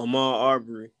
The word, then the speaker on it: Ahmaud Arbery: I don't know how to say his name. Avery. – Oh, Ahmaud Arbery.